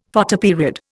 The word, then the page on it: Photoperiod: Indian